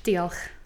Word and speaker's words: Diolch: Diolch.